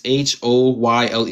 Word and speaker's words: Hoyle: Fred Holly. Not, I don't know how to pronounce his last name. It's Hoyle.